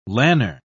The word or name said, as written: lanner